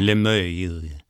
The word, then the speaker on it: Limeuil: How do you say it? – Limeuil. – Limeuil.